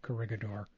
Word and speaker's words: Corregidor: And how would you how do you correctly pronounce the name? I've always said Corregidor. – It's Corregidor. – Corregidor? – It's actually a Spanish word, yeah. The Spanish named the island.